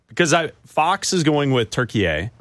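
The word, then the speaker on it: Türkiye: They pronounce it turkey A. – Turkey A. – Okay, like, so over we'll there, it's pronounced turkey A.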